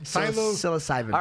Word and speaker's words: psilocybin: Psilocybin? Right. How do you say it? – Psilocybin.